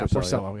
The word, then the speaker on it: Porcello: It's Porcello is how you pronounce it? – Yeah, Porcello.